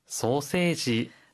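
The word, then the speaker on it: 소세지 (ソーセージ): 하이, 소세지는 시가 아니라 세로 발음을 해야 하고요. 함으라고 할 때 끝의 소리를 무로 발음합니다.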